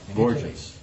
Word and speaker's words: Borgias: I guess there's even, I think it's the, I'm not sure how you pronounce it, but Borgias or? – Oh, the Borgias. Yeah. – Borgias.